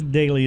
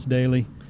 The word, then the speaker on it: Doelle: Well, because Daly is spelled so weird, D-O-E-L-L-E, all you have to do is search for Chris Daly. D-O-E-L-L-E, and you'll find me.